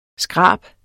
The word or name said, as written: skrab